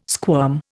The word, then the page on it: squirm: Indian